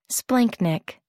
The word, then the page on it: splanchnic: American English